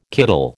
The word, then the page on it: kiddle: American English